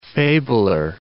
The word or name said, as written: fabler